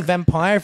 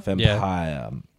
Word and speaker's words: Vampyr: do you just say vampire if it's V-A-M-P-Y-R? – I think that's what they're going for, right? – Yeah.